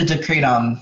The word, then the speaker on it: Decretum: Is it Bouchard's Directum or Bouchard's Directum? – Oh, yeah. Yep, the Decretum. – Decretum, there we go.